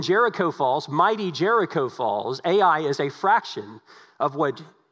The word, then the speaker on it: Ai: And so when Jericho falls, it's not as though the city that we're going to learn about here in chapter 7, Ai, literally Ai, and that's how you say it, Ai, wasn't going to come out of their walls and come to the rescue of those in Jericho.